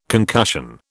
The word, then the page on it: concussion: British English